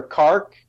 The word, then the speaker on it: Qark: And, I have my own post dubstep album now, and it's under Kark. It's spelled Q-A-R-K.